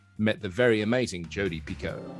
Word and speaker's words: Picoult: I would go Pico. – Is that the right thing? – That so is, if I said believe that is the correct pronunciation. Picoult would be wrong.